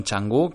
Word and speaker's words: Canggu: que son Canggu, que es una comunidad, se escribe Kangu con dos Gs, ¿vale? Con C, pero las, las Cs ahí se pronuncian como una X.